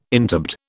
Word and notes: interbed: British English

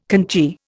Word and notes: conchy: Indian

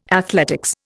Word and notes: athletics: Indian

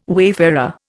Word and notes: wayfarer: Indian

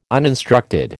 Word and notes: uninstructed: American English

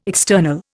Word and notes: external: Indian

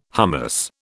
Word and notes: hummus: British English

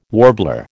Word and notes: warbler: American English

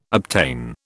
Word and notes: obtain: British English

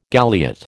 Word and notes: galliot: American English

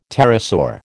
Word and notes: pterosaur: American English

